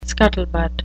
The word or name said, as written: scuttlebutt